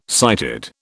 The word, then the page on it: sighted: British English